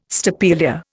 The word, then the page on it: stapelia: Indian